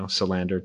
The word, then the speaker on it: Salander: If you think of the movie Girl with a Dragon Tattoo, her last name is Lisbeth Salander. – Oh. – And they have the emphasis on the and, you know, Salander, Tellander. So it would be accurate, but my whole life, you know, my dad and his dad, they all said Tellander.